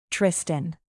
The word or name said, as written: tristan